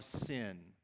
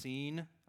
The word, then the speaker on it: Sin: And the text says here that they enter into the wilderness of sin. Now, I recognize in Hebrew it's probably pronounced seen.